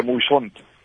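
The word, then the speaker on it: Muyshondt: Aquí en El Salvador le pronuncian Mason, pero sí, se pronuncia Mushidón.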